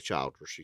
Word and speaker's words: Childress: I said Childress. – If you would like to say Childress, you can. – That's how it's pronounced.